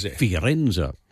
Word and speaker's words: Firenze: How are you going to pronounce Florence in Italy? – Because it's Firenze.